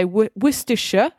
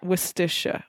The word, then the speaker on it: Worcestershire: So you would say Worcestershire. It sounds like three syllables. – Yes. – Is that right? Worcestershire, Worcestershire. – I mean, so it would be Worcestershire, but we do it as Worcestershire. We kind of do that, that linking the syllables together so that the actual city of the main city of this county is Worcester.